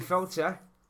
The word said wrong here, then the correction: Peltzer: Playing Billy Felt... – Billy? – Billy Peltzer is Zach Galligan. – Yeah, that's the right pronunciation.